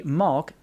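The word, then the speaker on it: Mauch: By the way, the Opera House that you were talking about, it's pronounced Malk, which I think Malk. That's what he means. – Okay. – M-O-W-K. – Okay. – Malk. – I pretty much butchered it, yeah.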